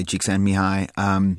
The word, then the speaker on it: Csikszentmihalyi: So I think flow, this is a state first identified by Mihaly Csikszentmihalyi.